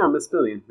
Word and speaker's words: Mispillion: Well, our first stop is the Mispillion River Walk. Am I saying that right? Mispillion? – Oh, give me a second, because I'm pretty sure I have a recommendation. Yeah, Mispillion. Yeah, that's how I'd say it.